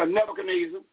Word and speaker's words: Nebuchadnezzar: Verse 28, all this came upon the king Nebuchadrezzar. Verse 29. I'm not pronouncing it right. – Yeah, Nebuchadnezzar. That's good. Nebuchadnezzar.